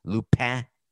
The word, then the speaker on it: Lupin: It's in French. Lupin is how to say it.